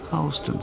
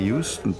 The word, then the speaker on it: Houston: In New York gibt es eine Straße, die wird genauso geschrieben wie die Stadt in Texas. Aber ausgesprochen wird sie Houston Street. Stattdessen sagen wir Houston, Texas, nach Sam Houston.